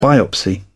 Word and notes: biopsy: British English

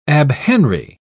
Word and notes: abhenry: British English

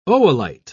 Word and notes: oolite: British English

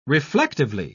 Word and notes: reflectively: British English

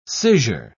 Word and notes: scissure: British English

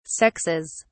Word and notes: sexes: British English

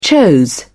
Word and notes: chose: British English

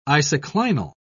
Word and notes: isoclinal: British English

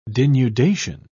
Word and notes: denudation: British English

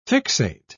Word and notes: fixate: British English

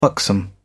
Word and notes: buxom: British English

